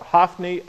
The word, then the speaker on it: Hophni: Hophni, or Hophni, as some would pronounce it. I call it Hophni and Phinehas.